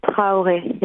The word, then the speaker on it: Traoré: Her and both the character of Suleiman, played by Traoré, I believe, is that how you say his name? – Ibrahima Traoré, yes, yeah.